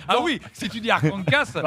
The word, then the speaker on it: Arkansas: Et donc la prononciation française du mot a été conservée. Et au fil du temps, cette prononciation est devenue un point de fierté qu'il fallait très bien prononcer. Donc il faut bien prononcer Arkansas.